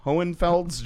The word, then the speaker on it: Hohenfels: Last week in Hohenfels. – Hohenfels, Germany.